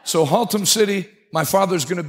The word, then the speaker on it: Haltom: Under the tent in, how do you pronounce that town? I just figured out Watauga. Now I got this Haltom. Say it again. Haltom. That shouldn't be that difficult.